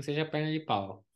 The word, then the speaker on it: Pau: Ele é de Pau, que é a cidade que se escreve pau na França, mas se lê Pau.